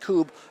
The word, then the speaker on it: Kubb: It looks like Cub, K-U-B-B. Is pronounced Coob.